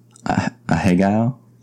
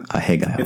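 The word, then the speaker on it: ahegao: Like if you're listening, a hegao. – I guess that sounds actually pretty good. – A hegao.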